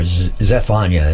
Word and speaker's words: Zephaniah: Then Zephaniah, or Zep, it says here, Z-E-P, or Zephaniah is actually the correct spelling and pronunciation.